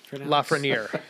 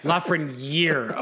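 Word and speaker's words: Lafrenière: Is that how you pronounce? – Lafreniere. – Lafreniere. Oh, okay.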